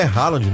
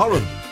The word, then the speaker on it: Haaland: E não é Haaland, né? É Haaland. Haaland. É uma pronúncia, cara. A gente fala tudo errado, sabia? – Haaland.